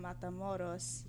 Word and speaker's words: Matamoros: High school was in Brownsville, which is a border town, and half of the kids at my school would come over from Matamoros every morning to go to school. – You pronounce that very well.